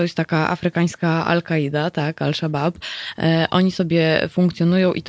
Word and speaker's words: Al-Shabaab: Panie Marku, mam nadzieję, że e, pytanie zostało odpowiedź pan uzyskał, natomiast jedziemy dalej, bo tutaj Al-Shabaab, tak powinno się wypowiadać? – No tak, tak chyba będzie najbardziej. Bo się pisze aż szabab i stąd moja wątpliwość. – Polska, po w polskiej pisowni pisze się aż szabab, ale w mm, międzynarodowej na miejscu, gdyby pani powiedziała al szabab, to wszyscy by zrozumieli o co, o co chodzi.